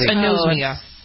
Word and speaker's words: anosmia: Is that how you pronounce it? Anosmia, insomnia. What is it? It's a loss of smell. – Yeah, it's anosmia.